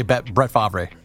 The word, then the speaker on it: Favre: That is how you say Brett Favre.